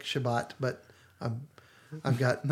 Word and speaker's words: Shabbat: Now, the Hebrew pronunciation for that is Shabbat. So you will hear the word Shabbat all the way through episode 2.